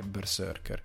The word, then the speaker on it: BRZRKR: Berserker, che è scritto. Brz- Brzrkr. e tutti quando lo leggono dicono ma come si pronuncia Berserker perché in verità è Berserker eh,